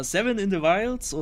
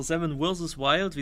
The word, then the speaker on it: Seven vs. Wild: Ja, Seven in the Wilds oder Seven vs. Wild, wie es ja richtig ausgesprochen wird, Seven in the Wilds ist dann eher so Knossi-Speech.